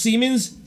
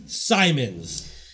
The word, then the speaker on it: Siemens: And they pronounced Siemens, Simons.